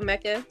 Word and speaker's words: Mecca: It's Hecava, Mecca.